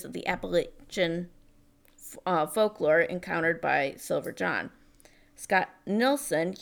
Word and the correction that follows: Nicholson: used... – Nicholson. – Nelson? – Nicholson. – Nicholson. I don't see how you get that part. – If you see Jack Nicholson's name, it's spelled the same way. Nicholson.